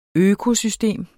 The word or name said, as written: økosystem